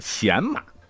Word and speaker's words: 洗马: 当时呢，这个官职即使是洗马这个喜字儿，也是读成太子贤马，就是专门给太子做侍从的，太子出行时当前导的。